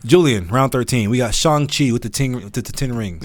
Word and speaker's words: Shang-Chi: You were the one that came on the pod and was like, man, we need to do better because it's pronounced Shang-Chi.